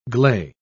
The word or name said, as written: gley